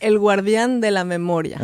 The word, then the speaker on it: El Guardián de la Memoria: How do you say that in Spanish? – El Guardián de la Memoria.